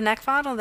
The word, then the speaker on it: Neckvatal: You can also find me on LinkedIn at Brenda Neckvatal.